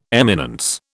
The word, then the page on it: eminence: British English